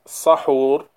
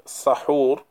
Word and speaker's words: sahur: Regarding this term suhoor, linguistically, it can be pronounced as sahur bil fath or suhur bil dam. So the seen in the word sahur can be fatha, sahur, and it can also be suhur with dhamma.